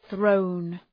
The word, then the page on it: thrown: ɵrəʋn